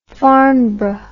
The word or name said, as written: farnborough